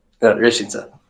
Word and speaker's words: Reșița: Did I say that right? – Reșița. – Reșița. – Yeah, Reșița. That's perfect.